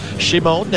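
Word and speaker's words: Šimon: Well, Shimon Nemec, his name is spelled Simon, but it's pronounced Shimon.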